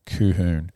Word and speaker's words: Colquhoun: And one of the junior coaches got in touch with me and said, mate, it is pronounced K-U-H-H-O-O-N. Colquhoun.